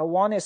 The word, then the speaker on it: wanted: 但是很多人你也会听到他把那个 T 就不发音了，直接读成 I wanted some advice, I wanted some advice, 我想得到一些建议，不是读成 wanted, 而是读成 wanted。